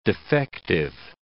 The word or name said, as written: defective